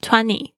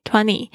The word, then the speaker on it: twenty: twenty twenty 它是有一个这样子的潜规则。通常这个 t 如果是在 n 的发音后面呢，通常有时候会被弱化，或者是干脆不就不念出来的。